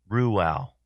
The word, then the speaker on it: Reuel: So I was pronouncing it wrong. It's Reuel. Reuel.